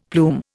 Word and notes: plume: Indian

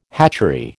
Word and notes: hatchery: American English

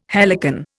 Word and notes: helicon: Indian

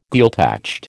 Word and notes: Gaeltacht: American English

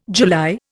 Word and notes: July: Indian

